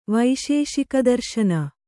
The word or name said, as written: ವೈಶೇಷಿಕದರ್ಶನ